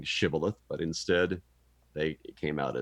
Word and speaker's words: Shibboleth: And so instead of saying, well, they probably thought they were saying Shibboleth, but when it came out, it was Sibboleth.